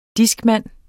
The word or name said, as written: discman